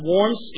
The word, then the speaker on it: Worms: That's spelled Worms, but pronounced Worms in German.